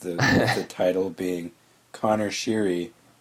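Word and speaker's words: Conor Sheary: It's that Connor Sheary actually told people who announce in the NHL that he would rather his name be pronounced Connor Sheary.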